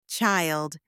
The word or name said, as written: child